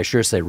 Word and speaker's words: Saoirse: And this is where, not only because the character played by Saoirse Ronan is such an endearing...